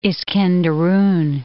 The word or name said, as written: Iskenderun